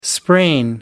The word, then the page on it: sprain: American English